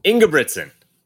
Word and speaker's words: Ingebrigtsen: The current Olympic 1500 meter champion, Jakob Ingebrigtsen, am I getting that right? – Ingebrigtsen. Close. – Not really close.